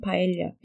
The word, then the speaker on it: paella: You mentioned, I'm not sure if we mentioned paella.